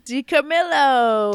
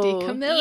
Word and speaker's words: DiCamillo: DiCamillo. – DiCamillo.